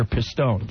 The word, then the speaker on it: Pistone: The correct pronunciation is Dr. Pistone.